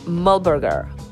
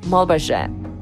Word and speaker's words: Muhlberger: Incidentally, Dana Kennedy pronounces his name Muhlberger. Others used the French Muhlberger.